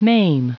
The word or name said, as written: maim